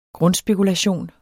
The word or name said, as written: grundspekulation